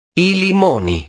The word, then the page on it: i limoni: italiano